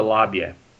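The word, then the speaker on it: Labe: That we call we call it the Labia. – Labia, yeah. The Labia, but in German it's Elbe.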